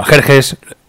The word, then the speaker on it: Jerjes: Bueno, ya anunció que sí va a ser el título, Xerxes. Bueno, Jerjes, son las dos formas de pronunciar este nombre,